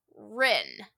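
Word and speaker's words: Ryn: So Rin is how I say her name. It's R-Y-N.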